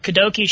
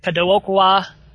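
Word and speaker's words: Kadokawa: The June flyer for Kadoki Shoten's Katakawa.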